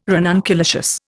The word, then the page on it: ranunculaceous: Indian